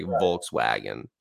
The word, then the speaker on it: Volkswagen: Like, I always I always pronounce it with an F, like, instead of a V, because everyone here is like Volkswagen. I'm like, Volkswagen. – Das Volkswagen. Auto. – Does it? Yeah, right. But I like automatically I pronounce, when I say VW, Volkswagen, I pronounce it with an F, because that's the way I learned to pronounce Volkswagen. – Yeah. – Even we had a trolley in Mexico because it was a Volkswagen. It's in B twice, the labial B twice, Volkswagen.